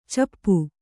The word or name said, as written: ಚಿಪ್ಪಿ